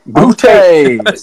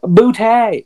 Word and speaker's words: Boutte: He's a player that I feel like a lot of people know, but he's the LSU receiver, Keyshawn Boutte. Okay, Boutte. – Say his name again for everybody listening. Keyshawn Boutte.